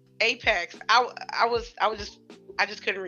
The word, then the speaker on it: Apex: Could Apex, because I was saying Aspex for like 2 weeks, so I finally said it right.